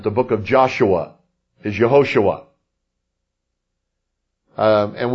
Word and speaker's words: Yehoshua: The J sound is always like a Y sound. It's not Jehovah, it's Yahweh, Y-A-H-W-E-H, the name of the Lord. Now, J-E-H, Jehosh, Hoshua, U-A. J-E-H-O-S-U-S-H, U-A. Pronounced Yehoshua. Now that means Jehovah is salvation.